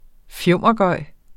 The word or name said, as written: fjumregøj